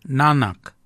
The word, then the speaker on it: nangag: So for neck, we say nanak, nanak. Nanak is spelled N-A-N-G-A-G. Nanak, nanak is your neck. Chin, we say yet, yet for chin. Yet is spelt Y-E-D.